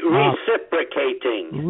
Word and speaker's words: reciprocating: But my license dated back to the precipitating. Listen to me, I can't even pronounce it. – Reciprocating. – Reciprocating. Did I say precipitating? I meant reciprocating.